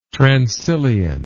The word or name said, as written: transilient